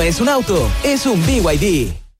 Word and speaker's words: BYD: Se escribe B&B, pero se dice BYD.